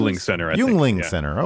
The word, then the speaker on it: Yuengling Center: Yuengling Center.